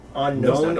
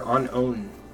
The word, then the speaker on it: Unown: Unknown no, or unown? – It is unown, yeah. – Yeah, it's unown. I always call him unknown. – That's how they pronounce it in the show, too, is unknown. But it's spelled unown.